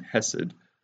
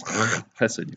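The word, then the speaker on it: chesed (חסד): We are tempted to not love God because we're not displaying hesed, which is covenantal loyalty. Actually, the Hebrew way of pronouncing it is chesed. You got to put the in there, like Ahmed.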